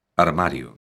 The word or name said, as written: armario